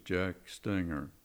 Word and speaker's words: Jack Stenger: Yes, my name is Jack Stenger.